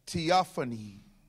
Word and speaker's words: theophany: Now, there's a word used, it's pronounced theophany.